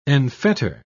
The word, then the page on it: enfetter: British English